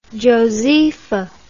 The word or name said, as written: Josepha